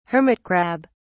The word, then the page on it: hermit crab: British English